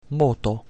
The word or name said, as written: modo